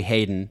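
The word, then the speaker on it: Haydon: So the Haydn character, I've been pronouncing it Haydn, it's actually Hayden, excuse me.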